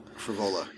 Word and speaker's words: Frevola: Yeah, Ferola's been around for, or how do you pronounce that? Frevola? – Frevola.